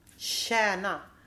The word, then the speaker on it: tjäna: you don't say it like this. You say sh. – Tjäna. – Like a sh sound or like a h? – More like a h. It's not like shown. It's like sh.